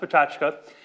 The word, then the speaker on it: pecha kucha: One of our trademark sessions over the past few years is an event called a speaking slot called a pachachko, which is a Japanese term spelled pecha kucha, but pronounced pachachka.